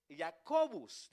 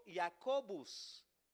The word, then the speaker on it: Jacobus: En latín, que son palabras también importantes, en latín es Jacobus. Diga conmigo, Jacobus. Jacobus, exacto.